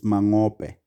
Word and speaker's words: Mangope: For proper pronunciation, it's Mangope. – ...budget was provided by South Africa.